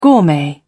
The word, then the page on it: gourmet: British English